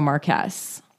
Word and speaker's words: Marquess: It was pretty high considering I've, never heard of a marquess. – How do you spell that? – M-A-R-Q-U-E-S-S. – Marquess. – Marquess. – So not like a marquee. That's a different thing, right?